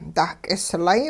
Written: dah-k'islay

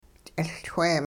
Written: ch'iLchwe

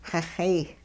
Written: xixeey

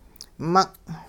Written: mung'

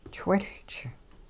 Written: chwiLch

